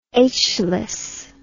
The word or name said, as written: aitchless